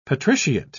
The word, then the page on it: patriciate: British English